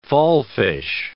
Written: fallfish

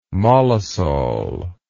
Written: mollisol

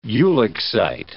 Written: ulexite

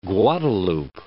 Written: Guadalupe

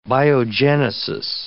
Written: biogenesis

